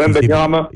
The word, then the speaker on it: Wembanyama: Wembanyama? – Yeah, they're saying he's even better. – He's not quite Wembanyama talent,